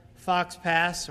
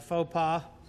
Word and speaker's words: faux pas: Now, I might have avoided that verbal fox pass or faux pas altogether had I just used a musical illustration.